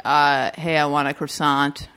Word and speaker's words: croissant: She says, that Beth Baer's character goes, oh, it's croissant. The T is silent.